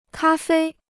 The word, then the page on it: 咖啡: kā fēi